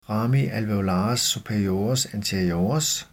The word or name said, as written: rami alveolares superiores anteriores